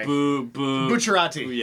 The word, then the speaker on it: Bucciarati: Bucciarati. – Yeah. Bucciarati. – Bucciarati.